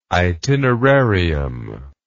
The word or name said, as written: itinerarium